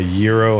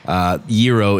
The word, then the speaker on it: gyro: We have a classic beef, a beef and pork hot dog, a buffalo chicken hot dog, a gyro hot dog, and a pepperoni hot dog. – And as you just heard, Tony is a connoisseur of all things Greece. Gyro is the proper pronunciation. It's not gyro.